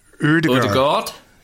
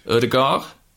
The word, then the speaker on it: Ødegaard: See, the Ødegaard. – Ødegaard? – Yeah, the D is silent.